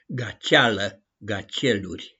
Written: GACIALĂ-GACIELURI

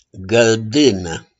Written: GĂLDÂNĂ

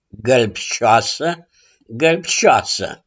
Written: GĂLBȘIOASĂ

